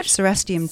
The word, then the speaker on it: Cerastium: Ceriastrum. right? – Cerastium tomentosum. – Cerastium, I always get that wrong.